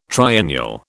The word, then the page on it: triennial: British English